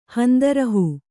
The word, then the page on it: ಹಂದರಹೂ: kannada